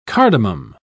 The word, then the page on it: cardamom: British English